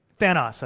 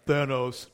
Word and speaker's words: Thanos: And I'm like, man, the good guys are going to beat this evil dude, Thanos, I think is how you say his name. – Thanos. Y'all never studied Greek. – Did y'all study Greek? Because it's actually Thanos.